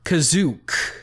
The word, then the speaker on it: Kashyyyk: He had some concept art for Wookiee homes, and they lived in the top of giant tall trees on the planet Kashyyyk, or as it's pronounced here, Kazook. – Yeah, I was wondering, was this supposed to be Kashyyyk or Kashyyyk? But they definitely say Kazook, so it's supposed to be the Wookiee planet, though. – Yes, it's spelled the same, but they pronounce it Kazook.